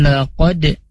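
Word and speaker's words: laqad (لقد): Wa laqad, nah di sini ada di kata qad ada qalqalah ya, dal sukun. Nah, cara bacanya itu memantul ya, ini masuk huruf qalqalah.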